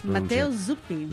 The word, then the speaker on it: Matteo Zuppi: Matteo Zuppi.